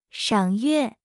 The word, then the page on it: 赏月: 0.8x